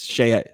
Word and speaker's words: Shai: I used to say Shai because it looks like it, but it's Shea Gildress.